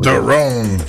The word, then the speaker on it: Theron: And I never say her name right, Charlize Theron. – Theron, yeah.